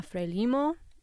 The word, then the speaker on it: Frelimo: that was Ife ana Frelimo. And that actually answers the question of how to pronounce Frelimo, the acronym.